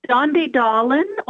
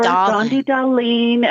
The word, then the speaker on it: Dondi Dahlin: Dondi Dahlin or Dondi Dahlin. Everybody says it different. It's a Swedish name, so it's almost never said correctly, but Dondi is perfect.